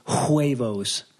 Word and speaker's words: huevos: The the the American way of saying huevos is huevos. – Huevos. – Yeah, because there's an H in there, but the H is silent, so huevo.